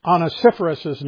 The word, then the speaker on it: Onesiphorus: And so this week I found out that I'd been mispronouncing Onesiphorus's name. That's how you pronounce his name.